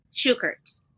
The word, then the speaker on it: Shukert: Is it Shukert? – Shukert.